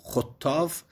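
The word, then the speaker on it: خطّاف: می فرماید والخطافو، خطاف به ضم الخا و تشدید التا، و هو سنونو، سنونو یعنی پرستو، اشد و چراحیتا من الهدهد، از هدهد چراحتش زیاده.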